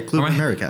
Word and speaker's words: Club America: Club America, is it Club America? – Yeah. – Am I saying that right? – Yeah, Club America. – Or am I confusing Club Leon? Okay. – No, you're correct.